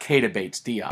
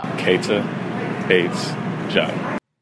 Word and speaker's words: Keita Bates-Diop: And when they know the game, they hear the name, though most of us have been saying it wrong. Not Keita Bates-Diop. – Keita Bates-Diop.